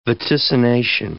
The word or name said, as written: vaticination